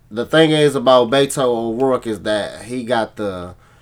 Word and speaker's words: O'Rourke: O'Rourke.